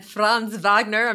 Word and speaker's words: Franz Wagner: We got Paolo and Franz Wagner.